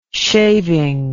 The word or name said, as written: shaving